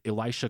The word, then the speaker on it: Elisha: Race also informs the remarks of the white morgue attendant, played by the classic character actor Elisha Cook Jr. Is that how I pronounce his name? – Yep. Elisha? Okay.